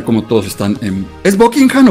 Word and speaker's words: Buckingham: Es Buckingham. – Y de hecho, el H medio que se es medio silente. Buckingham.